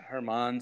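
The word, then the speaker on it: Germán: Yeah, Herman, spelled like you would spell German.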